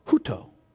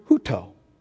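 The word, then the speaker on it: huto (οὕτω): The word that's translated in English in John 3:16 as so is the Greek word huto. It's the Greek word huto. Okay? Which means, you want me to spell it? H-O-O-T-O. Huto.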